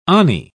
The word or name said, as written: ani